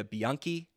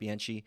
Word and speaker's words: Bianchi: I've been saying this wrong for a long time. It's Julia Bianchi, not Bianchi.